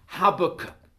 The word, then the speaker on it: Habakkuk: I don't like the American pronunciation of Habakkuk. It sounds like something to do with tobacco. So we'll just stick with Habakkuk.